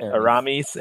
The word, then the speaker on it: Aramis: All right, my next one, I'm going to pick Aramis. Is that how you pronounce it? – Mm-hmm. – All right, Aramis, A-R-A-M-I-S.